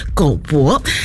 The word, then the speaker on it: GOBO: 所以呢再搜寻了一下呢，这一位呢独立乐团美秀集团的主唱，他的本名是叫做黄博汉，所以呢他的这个英文名还有这个社群的账号呢也就是直接的拼作 GOBO，所以呢我们就是把它念成了狗博。